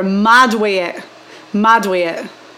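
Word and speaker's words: mad wae it: If you saw someone and they were mad way it. – Spell it M-A. – So M-A-D and then W-A-E and then I-T. Three words.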